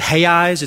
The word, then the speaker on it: reais: We pronounce it Reais. It's actually spelled R-E-A-L, but apparently the R is an H sound,